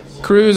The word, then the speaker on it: krewes: It's Cruise, and just a little bonus tidbit. Cruise is spelled K-R-E-W-E-S. – Wow. – Cruise. – Weird. – Because that's French.